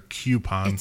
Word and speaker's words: coupon: Is it coupon or coupon? – It's coupon. – Coupon? – I hate when people say cute. It's not cute. It's not spelled with the Q.